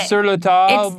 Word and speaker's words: Sur La Table: You don't pronounce the L, E, Sur La Table. – Oh, so we were both wrong. No, it's sur la table.